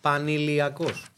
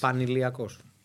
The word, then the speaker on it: Paniliakos: I can't pronounce it. – Pani. Pani Paniliakos. – Yes. – Paniliakos.